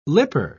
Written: lipper

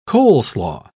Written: coleslaw

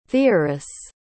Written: theoris

